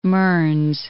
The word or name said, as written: The Mearns